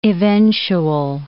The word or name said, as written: eventual